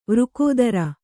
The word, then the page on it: ವೃಕೋದರ: kannada; vřkōdara